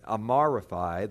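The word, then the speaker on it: Amraphel: Chapter 14, verse 1 says, "And it came to pass in the days of Aramaphi, the king of Shinar." By the way, people think that Amaraphi, the king of Shinar, I should pronounce it correctly, is another name for King Hammurabi, the Babylonian king who put together the code of Hammurabi that you've heard of before.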